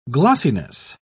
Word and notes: glossiness: British English

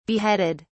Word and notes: beheaded: British English

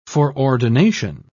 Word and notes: foreordination: British English

